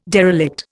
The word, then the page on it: derelict: Indian